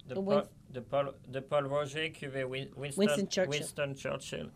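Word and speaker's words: Pol Roger: Paul Roger. – Paul Roger. Yeah. Right. – Now, I can say it like Edouard. Paul Roger. Paul Roger.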